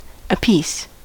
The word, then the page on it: apiece: American English